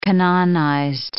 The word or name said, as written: canonized